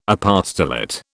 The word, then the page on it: apostolate: British English